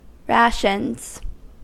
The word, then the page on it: rations: American English